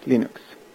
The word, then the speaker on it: Linux: Hello, this is Linus Torvalds and I pronounce Linux as Linux.